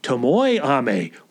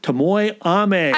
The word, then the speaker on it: Tomoe Ame: On next week's episode, we get back to our trek down the Wanderer's Road with Miyamoto Usagi and Tomo Ame. Or is it Tomoe Ame? We had a correction. We will follow that correction. That's the proper pronunciation, apparently. Tomoe Ame.